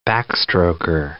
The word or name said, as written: backstroker